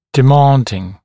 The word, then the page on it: demanding: dɪ’mɑːndɪŋ; ди’ма:ндин